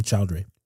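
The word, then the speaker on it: Chaudhry: Asim Chowdhury.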